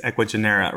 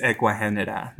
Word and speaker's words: Ecuagenera: our American name is Ecuagenera, right? That's our American name. But it is Ecuagenera. That is how it's pronounced, Ecuagenera, which actually means Ecua for Ecuador and Henera for genia.